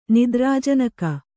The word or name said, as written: ನಿದ್ರಾಜನಕ